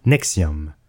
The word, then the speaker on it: NXIVM: Elle s'écrit, avec les chiffres romains, NXIVM et se prononce Nexium.